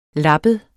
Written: lappet

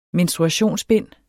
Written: menstruationsbind

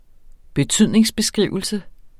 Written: betydningsbeskrivelse

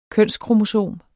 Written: kønskromosom

